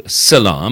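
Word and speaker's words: Psylla: So, and that thing is called Scilla. Am I pronouncing it correctly? – You are pronouncing it correctly. Precisely, yes.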